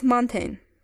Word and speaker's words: mountain: The word is mountain. Mountain. – Correct. Mountain. Not mountain, but mountain. Esta vez, la combinación A i suena diferente, como la E en la palabra ten, el número 10.